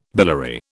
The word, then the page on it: biliary: British English